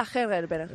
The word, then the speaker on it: Ada Hegerberg: Curiosidad, la futbolista que ha metido los tres goles, que se pronuncia ¿cómo exactamente? – Ada Hegerberg.